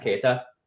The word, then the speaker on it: Keyra: I'm going to go Kita. A great luchadora who's finally getting some time in AAA. And wait, who the fuck? Sorry? – Her name is spelled K-E-Y-R-A. That's pronounced Keita.